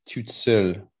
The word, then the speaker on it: Tout Seul: and I was purchasing a copy of Tout Seul by Chabutier. You can correct my pronunciation. – Yeah, that sounds right.